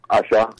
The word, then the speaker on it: Asa: My first one is do you pronounce it Asha? Asha? A-S-A? – Asha. – Asha. Yeah.